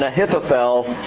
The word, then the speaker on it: Ahithophel: Pronounced correctly, it should be Ahithophel. But I'm not going to say it that way because it's just too hard, okay? Ahithophel.